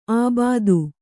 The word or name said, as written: ಆಬಾದು